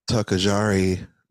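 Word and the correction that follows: togarashi: mac. Tagarashi mac salad. Yes, that's how you say it.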